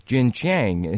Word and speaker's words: Xinjiang: But Senator Marco Rubio has proposed an amendment recently to legislation that would have banned imports from Xinjiang. Here again, we're losing the battle because we can't pronounce these things. How do you pronounce that? It's spelled X-I-N-J-I-A-N-G.